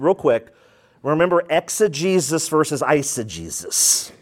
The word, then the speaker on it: eisegesis: Eisegesis, and the way you remember that is the first syllable there in that word, I.